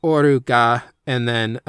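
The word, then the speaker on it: Ga (我): which is pronounced uh, Ga, but would normally be read as uh, Ware. It's like an archaic uh, personal pronoun for I, um, like me, the self.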